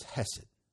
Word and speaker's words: hesed: Mercy comes from a Hebrew word, which just for the sake of trivia, the Hebrew word is hesed. Hesed.